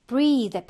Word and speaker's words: breath: 그 다음에 breathe 발음을 좀 주의해야 되고요. breathe 이로 끝나죠? 그럼 동사로 숨을 쉬다인데요. 명사는 끝에 이가 없어. 그 발음도 breath. 좀 달라요. 그래서 얘가 숨, 호흡이 되고, 동사는 breathe 라고 발음을 합니다.